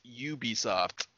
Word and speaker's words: Ubisoft: It's not Ubisoft. It's Ubisoft.